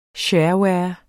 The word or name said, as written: shareware